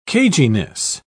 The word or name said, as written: caginess